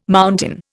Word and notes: mountain: Indian

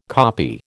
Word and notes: koppie: American English

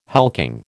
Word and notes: hulking: American English

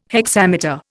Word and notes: hexameter: Indian

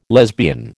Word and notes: lesbian: American English

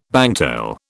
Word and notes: bangtail: British English

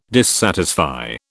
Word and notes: dissatisfy: British English

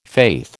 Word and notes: faith: American English

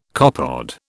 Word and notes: copepod: British English

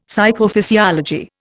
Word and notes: Psychophysiology: Indian

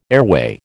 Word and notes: airway: American English

